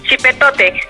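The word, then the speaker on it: Xipe Totec: Oye, en primer lugar, ¿cómo se pronuncia? ¿Es Xipetotec o Xipetotec? – Xipetotec. – Xipetotec. – Ok, sí.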